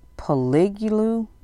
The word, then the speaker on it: Poliglu: Number two, I believe this is pronounced the Poliglu, P-O-L-I-G-L-U.